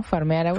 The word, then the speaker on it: farmear Aura: Lo he dicho bien, ¿no? Farmear Aura. – Farmear Aura, sí.